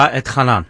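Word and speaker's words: Va'etchanan: And for those that want to follow along, we're in Deuteronomy 3, in a portion, hard for me to pronounce, Vatchanan. How do you do it, Rabbi? – Yeah, you said it right. Okay. Vatchanan. Okay. That's correct.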